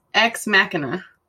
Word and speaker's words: Ex Machina: It's Ex Machina. – Machina in Italian is car, so it's... It was easy for me to figure it out. Yeah. Machine, yes, yes, yeah. Mm-hmm. So Ex Machina came out in, it says 2014, I guess, is when it premiered, but went wide in 2015.